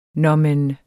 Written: nåmen

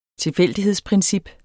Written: tilfældighedsprincip

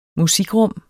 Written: musikrum

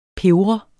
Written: pebret

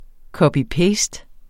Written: copy-paste

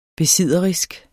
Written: besidderisk

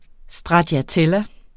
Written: stracciatella

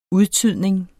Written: udtydning

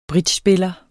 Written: bridgespiller

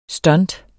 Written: stunt

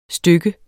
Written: stykke

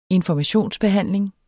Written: informationsbehandling

